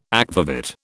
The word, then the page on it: akvavit: British English